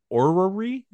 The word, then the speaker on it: orrery: The Skeksis distracted Agra from tending to Thrall by building her an orrery. Is that how it's pronounced? – Yes.